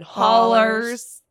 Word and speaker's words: hollers: And we have so many like hollows and hills. I didn't say it right. It should have been hollers and hills and woods and so many unexplored places.